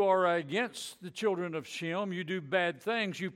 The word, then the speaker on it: Shem: Now, Shem, they didn't pronounce the H in ancient times, so his name was pronounced Sim.